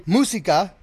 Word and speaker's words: Música: I go by música, which is music in Spanish. Most people just call me music because they cannot pronounce it correctly.